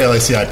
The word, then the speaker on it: LACI: At Lacey, isn't that how you say it's Lacey, right? – We used to say LACI. People say Lacey now, okay.